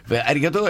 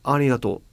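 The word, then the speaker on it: arigato (ありがとう): La pronuncia, diciamo, base giapponese sarebbe arigato. – Ma un suono è maschera, è arigato. – Arigato. – Arigato. – La G giapponese è un po' più gutturale della nostra. Arigato.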